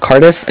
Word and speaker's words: Cardiff: It's Cardiff. It's spelled C-A-R-D-I-F-F.